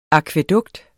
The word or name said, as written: akvædukt